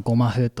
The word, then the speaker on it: ゴマフ: 漢字で書いてあのゴマ風と読む